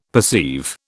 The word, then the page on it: perceive: British English